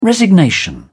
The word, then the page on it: resignation: British English